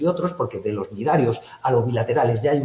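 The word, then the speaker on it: cnidarios: Estos ctenóforos y nidarios, los dos que empiezan por una C que no se pronuncia, ctenóforos y nidarios, son en los que aparece la neurona.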